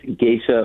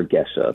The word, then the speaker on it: Gesha: The original name comes from a region of Ethiopia, so it's spelled G-E-S-H-A, so it's pronounced Geisha, but it's also, it's added someplace along the way, it got this I added, and it's also said as Geisha.